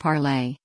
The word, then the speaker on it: Parler: Breitbart News sat down with John Mazza, the CEO and co-founder of the new social media app Parler, pronounced Parlay, which describes itself as a non-biased free speech-driven entity.